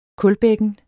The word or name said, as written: kulbækken